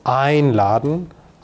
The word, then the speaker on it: einladen: So it's about the stress. So with the, or for these separable verbs, you have the stress on the first syllable. So einladen, anfangen, and abholen, I'm exaggerating here to make it clear.